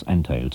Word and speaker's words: Antheil: Nicht nur, um sie auf diese politische Denunzierung aufmerksam zu machen, ziehen wir es vor, die deutsche Aussprache des Namens Anteil zu benutzen, sondern auch, weil Anteils Vorfahren deutsch-polnischer Abstammung waren und weil sich die deutsche Aussprache schließlich besser einprägt.